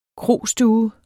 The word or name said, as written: krostue